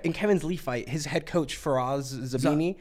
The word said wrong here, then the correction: Zahabi: from TriStar. – Faraz Zahabi. – Zahabi, thank you.